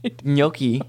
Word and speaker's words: gnocchi: Gnocchi. – There's nothing wrong with mispronouncing words. Like, it's morally neutral and fine. But something being mispronounced as Yonkies is true.